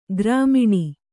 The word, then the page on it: ಗ್ರಾಮಿಣಿ: grāmiṇi